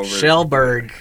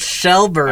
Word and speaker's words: Kjellberg: Shellberg. – I don't care. – It's pronounced Shellberg.